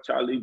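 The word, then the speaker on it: Chalee: IG comedian underscore Charlie Jr. I'm country. So that's C-H-A-L-E-E-J-R. Don't put the R in my name.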